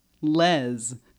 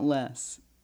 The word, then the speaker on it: Les: Just wanted to point out that in the UK, the name Les is pronounced Les. Not less. Thanks a lot, Andrew.